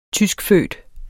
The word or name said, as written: tyskfødt